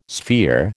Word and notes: sphere: American English

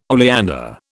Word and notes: oleander: British English

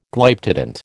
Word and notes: Glyptodont: American English

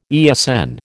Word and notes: ESN: American English

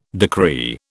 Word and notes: decree: British English

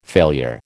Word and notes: failure: American English

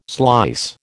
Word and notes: slice: American English

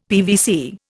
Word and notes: PVC: Indian

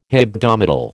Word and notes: hebdomadal: American English

